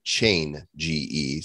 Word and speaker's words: Chainge: Again, it's spelled like chain, G-E. Just throw an I in there and in the word change. Chainge.Finance on Twitter at Finance Chainge.